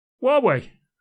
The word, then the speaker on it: Huawei: I'm sorry, could you say Huawei again? – Huawei. – Okay. – Did I not say Huawei or Huey or whatever? – Yeah, okay. – Huey. Well, that's the way it's spelt.